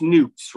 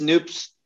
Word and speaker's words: Noops: And it's pronounced Noops, right? – Yep, it's Noops, which is spoon spelled backwards.